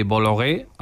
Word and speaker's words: Bolloré: Más allá del cine, Antonio, la controversia sobre la influencia de Bolloré, ahora sí lo digo bien, en el debate público francés es muy frecuente.